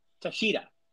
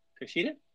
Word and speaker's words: Takeshita: I'm gonna say it wrong, Tashida. – Takeshita.